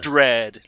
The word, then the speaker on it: Dredd: Dredd, not Judge Dredd. – Yeah, and it rhymes with Fred, so it's easy to remember.